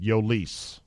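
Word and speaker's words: Jhoulys: Smart money's on Yolis Chacin, but God only knows the way they've been managing it so far. Who knows? – And that's how you pronounce it, Yolis.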